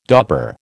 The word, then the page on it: dauber: American English